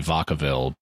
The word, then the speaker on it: Vacaville: I think Vacaville. – We still need El Cajon, though. They need to bring that ship online. – Yeah. Is it Vacaville or Vaca? – It's Spanish, but so it'd be Vacaville.